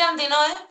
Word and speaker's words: Antinoë: ¿Lo pronuncio bien, Antínoe, o cómo se...? – Bueno, yo suelo decir Antínoe porque en realidad viene de... Es el nombre de una ciudad en femenino, en griego.